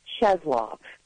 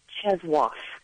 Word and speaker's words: Czesław: Cheslov. Yes, C-Z-E-S-L-A-W, but it's supposed to be pronounced Cheswoff.